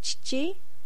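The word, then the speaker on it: chichi (ちち): And its pronunciation is chichi or to, as in otosan.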